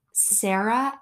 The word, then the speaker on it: Sara: I would also just like to make clear our housekeeper, beloved Sara, her name is Sarah, S-A-R-A, but given that she is from Nicaragua and the way that she pronounces her own name, it is Sara.